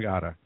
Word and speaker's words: Smagata: I have to ask, why the silent S in your name? Is that just to mess with the Canonistas or what? – The silent S in Magata? – Yeah. Magata. No. Oh, it's Smagata? I'm sorry.